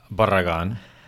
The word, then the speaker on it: Barragan: Barragan.